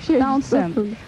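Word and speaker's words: Townshend: Как мы говорим его по-английски, все время посылают в город. – Где-то бывает, где-то должно же прибавиться. – Да, там звучит точно «Таунсенд».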